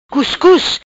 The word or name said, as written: Cuscus